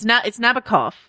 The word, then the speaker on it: Nabokov: So I believe it's Nabokov. I believe that's correct.